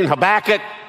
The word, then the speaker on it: Habakkuk: turn to Habakkuk. And, you know, I was from East Texas, and so I said, you mean Habakkuk? And he said, yes, that could be your primitive pronunciation.